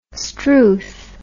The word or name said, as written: struth